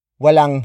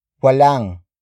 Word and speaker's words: walang: Walang himala. It means there are no miracles or there is no such thing as a miracle. Word for word it's walang himala. Walang himala. Walang himala. The first word is walang. Walang. Walang. Walang is spelled as W-A-L-A-N-G.